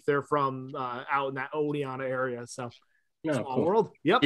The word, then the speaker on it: Oneida: Oh, Oneida.